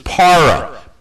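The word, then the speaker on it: para (παρά): The Greek word for with is para, P-A-R-A. It's the same as in Spanish. Is that correct? Para? P-A-R-A?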